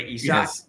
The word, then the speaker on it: Isak: Like Isaac, but Isak.